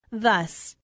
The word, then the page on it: thus: female